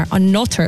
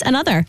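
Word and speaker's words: ANOTR: It's spelled A-N-O-T-R, a notter it looks like, but they're pronounced another.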